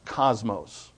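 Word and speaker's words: kosmos: In the original Greek, it's K-O-S-M-O-S, cosmos. It's not actually, the Greek doesn't have a C. They have a hard K, and they have a C-H, which is also a hard-sounding K. It sounds like a K.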